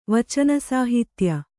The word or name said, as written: ವಚನಸಾಹಿತ್ಯ